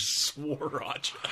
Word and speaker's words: Sriracha: Sriracha. – How do you say it? – Sriracha.